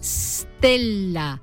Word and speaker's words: stella: En latín se escribía stella y se pronunciaba con S líquida, es decir, stella,